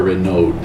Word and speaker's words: Renaud: Is it Renaud or Renaud? Is there like a... When it's R-E-N-A-U-D, do we pronounce the D? – R-E-N-A-U, yeah. Oh, no, you don't pronounce the D. – So it's Renaud? – Renaud.